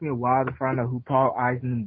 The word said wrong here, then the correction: Eiding: Eiding, not Eisen. – My God, you can't even pronounce the dude's name. Paul Eiding, not Eising.